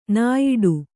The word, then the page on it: ನಾಯಿಡು: nāyiḍu